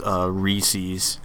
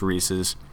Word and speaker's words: Reese's: And one of the most common ones that just grinds every gear in my body is when people pronounce the peanut butter cups as Reese's and not properly as Reese's.